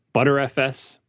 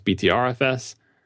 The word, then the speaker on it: Btrfs: It was Hypercritical episode 56, Computational Skeuomorphism, where we talked about ZFS, ButterFS, which I also like to pronounce BTRFS, block-level diffs, deduplication, time machine, all sorts of file system stuff.